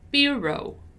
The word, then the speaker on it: bureau: Notice that in bureau, my stressed syllable is bu, bureau, bureau, bureau, right?